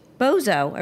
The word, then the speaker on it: Bozo: How do you say it? Bozy the Clown? Am I saying that right? – Bozo.